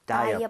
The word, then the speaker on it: diaper: D-I-A-P-E-R. 근데 이제 복수로 쓴 겁니다. 이것들은 어때? 라고 하면서. – Yeah. – 자, 원래는 발음이, 발음 표기를 보면, diaper 예요. diaper. – Diapers. – 근데 이걸 빠르게 하다 보면요. 거의 어가 사라집니다. 그래서 거의 diaper. – Oh. – diaper. 오케이. – Diaper. – 그래서 너무 억지로 다이어퍼 이렇게 하지 마세요.